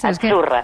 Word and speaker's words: Achurra: La Z no es la Z como decimos en catalán tan sonora, no. Es achurra. – Muy bonito, muy bonito. – Es como pizza.